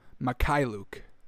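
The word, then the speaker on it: Mykhailiuk: Mikhailuk. Oh, that's easy. Savie Mikhailuk. Oh, interesting. Alrighty. It's as simple as that, folks. You just got to plug it into the old Google Translate. Alright, so Savie Mikhailuk.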